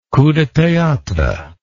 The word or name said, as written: coup de theatre